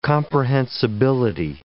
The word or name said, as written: comprehensibility